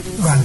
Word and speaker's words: đoàn: Thực năng bất đoàn thực, chữ này đừng có đọc là chữ bát, nên đọc là chữ đoàn.